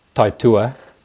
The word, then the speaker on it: Taitua: And that's a quirky word. The pronunciation is Taitua. It's a New Zealand Maori word.